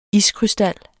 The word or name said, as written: iskrystal